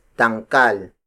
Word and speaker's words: dangkal: The next unit of native measurement is dangkal. Dangkal. Dangkal. Dangkal is spelled as D-A-N-G-K-A-L.